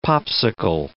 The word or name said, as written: Popsicle